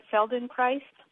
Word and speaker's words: Feldenkrais: So, in terms of, am I pronouncing it right, Feldenkrais? – Yes.